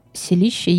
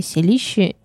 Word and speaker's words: селище: У слова «селище» есть два ударения – «селище» или «селище».